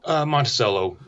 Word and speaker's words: Monticello: Well, how do you pronounce it? Monticello? – Monticello. – Monticello. Okay. Yeah, because the one up in New York where I play with the Stooges, they say Monticello.